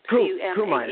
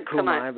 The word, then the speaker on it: Cumae: Cumae is fine. I would say Cumae, but people say it different ways.